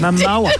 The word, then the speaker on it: Momoa: I was sure you said his name wrong, but you Momoa. Think of Moa, mowing the lawn.